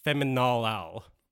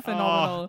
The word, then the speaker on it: phenomenal: It is feminolal. – And she sure says it like that. You're right. She doesn't know how to say the word phenomenal.